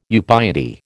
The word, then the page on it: ubiety: American English